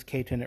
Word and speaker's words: Kaiten: His name is Kaiten. It rhymes with Satan, but it's K-10, like the mountain.